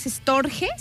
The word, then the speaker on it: storge: Después está el Storge o Storch, no sé, es Storge, se escribe con G, Storge.